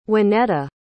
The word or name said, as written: waneta